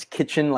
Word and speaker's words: Kitchen: Okay, well, I always laugh because a lot of people I'll say my last name is Kitchen, like the room, and then they say, "How do you spell that?"